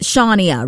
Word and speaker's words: Shawnia: That's spelled S-H-A-W-N-I-A. But I've actually had my name mispronounced quite a number of times now, okay? I've gotten anything from like Shawina to like Shawina to even Shawnina. I don't even know where that double N came from, okay? I just got one in my name.